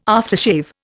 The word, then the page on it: aftershave: Indian